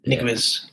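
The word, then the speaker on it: Ñíguez: Niguez.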